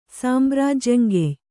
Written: ಸಾಂಬ್ರಾಜ್ಯಂಗೆಯ್

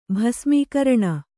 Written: ಭಸ್ಮೀಕರಣ

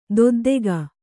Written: ದೊದ್ದೆಗ

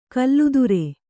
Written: ಕುಲಾದ್ರಿ

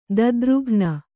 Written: ದುದ್ರುಘ್ನ

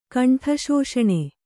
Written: ಕಂಠಶೋಷಣೆ

